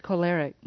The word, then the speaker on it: choleric: I can see the first one for sure, sanguine, and then I think my secondary is how do you say the word? Choleric? – Choleric. Mm-hmm.